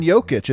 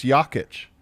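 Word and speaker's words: Jokić: We all say Nikola's name wrong. It's Nikola, and it's not even Jokic. It's Jokic.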